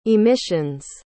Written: emissions

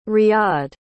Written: riyad

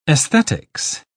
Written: aesthetics

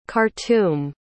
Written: khartoum

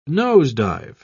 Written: nose-dive

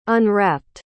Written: unwrapped

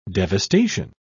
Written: devastation

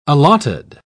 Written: allotted

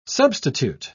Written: substitute

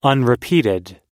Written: unrepeated

